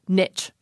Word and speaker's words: niche: So this, again, borrowed in from French in the 1600s, and it quickly came to be pronounced in an English way, which would be niche. According to American Heritage, in the 1900s, some people started to adopt a more French pronunciation, which would be your pronunciation, niche.